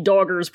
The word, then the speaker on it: Doggers: So I'd like to introduce everyone to my new OC Third Base, or better known as Richard Dobby Doggers, pronounced like Dodgers. – Okay, like the team.